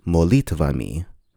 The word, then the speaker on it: молитвами: Very roughly transliterated, you might spell this with the Latin alphabet by spelling it M-O-L-I-T-V-A-M-I. Now you'll notice that the stress is on the E, so it would be pronounced molitvami. However, in Russian, because the stress is not on the O, it will be reduced so that the word sounds like molitvami.